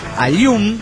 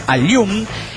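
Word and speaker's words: Aaiún: Voy a decir cómo se pronuncia Ayum, así me dijo Poyatrón, Ayum, pero se escribe A-A-I-U con tilde N.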